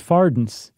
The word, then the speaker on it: farthings: that they used to pronounce farthings as fardens. – Oh, interesting. – Or at least it's something that rhymed closely to gardens.